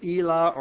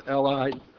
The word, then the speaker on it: Elah: And somewhere right in the middle, so about 13 miles east of Jerusalem, is the Valley of Elah, or the Valley of Elah. I've heard it pronounced both ways.